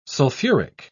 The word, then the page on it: sulfuric: British English